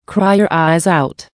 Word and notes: cry your eyes out: American English

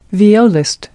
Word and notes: violist: American English

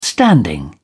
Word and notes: standing: British English

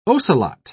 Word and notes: ocelot: British English

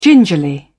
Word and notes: gingerly: British English

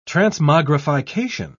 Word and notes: transmogrification: British English

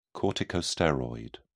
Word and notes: corticosteroid: British English

